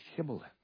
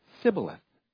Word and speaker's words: Shibboleth: In the same way, the Ephraimites had a problem pronouncing that soft S-H, Shibboleth. Instead, they said Sibboleth.